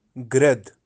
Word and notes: Grad: Kashmiri

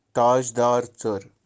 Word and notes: tajdaar-tser: tsar

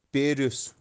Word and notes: peryus: Kashmiri

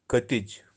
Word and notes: katij: Kashmiri

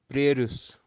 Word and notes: preryus: Kashmiri

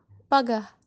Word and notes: pagah: Kashmiri

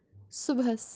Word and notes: subhas: Kashmiri

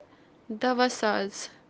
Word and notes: dawasaaz: kashmiri

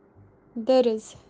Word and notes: duruz: kashmiri

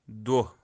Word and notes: doh: kashmiri

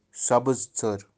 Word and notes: sabiztser: tsar

